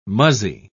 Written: muzzy

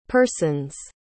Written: persons

